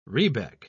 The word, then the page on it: rebeck: British English